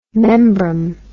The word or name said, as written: membrum